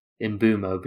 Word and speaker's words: Mbeumo: I think it's Mbeumo, that's how they say it.